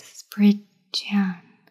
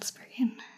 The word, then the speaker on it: Spriggan: Although I have said Spriggan, it's actually pronounced Spridjan, not Spriggan. You need to emphasize the D in Spridjan. And then say Jan as in the word January.